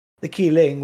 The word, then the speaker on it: Qilin: Kirin is the Japanese pronunciation. The Chinese pronunciation is Qilin. Well, Qilin. – I mean, it's the anglicized version, but yeah, Qilin is the Chinese version.